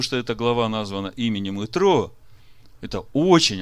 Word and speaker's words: Итро: На иврите имя Иофор здесь написано четырьмя буквами. Е, Т, Р, Вав. И читается как Итро.